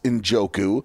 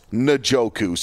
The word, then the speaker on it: Njoku: I always thought it was Njoku. And no, it is Njoku.